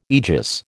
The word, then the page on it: aegis: American English